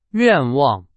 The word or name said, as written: 愿望